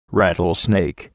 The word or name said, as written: rattlesnake